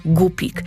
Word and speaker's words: gupik: Nazwę gupik pisze się przez U, w języku angielskim mówimy guppy, ale po polsku to ciągle jest gupik. Tylko pamiętajcie, tam nie ma głoski ły w środku. To nie jest rybka głupia. O, nie, nie. Rybka jest bardzo sprytna, bardzo mądra. Dlatego gdy wymawiacie jej nazwę, pamiętajcie, żeby tam żadnego ły nie wciskać.